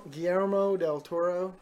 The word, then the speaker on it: Guillermo del Toro: And I Guillermo del Toro. – You said the name right, but I'm like, what?